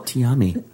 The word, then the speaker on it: Tiaamii: Oh, God, Princess Tiami Andre. Because they combined their mom's names to come up with Tiami. And they also... – Tiami. – Mind you, it's spelled T-I-A-A-M-I-I.